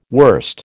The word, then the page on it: wurst: American English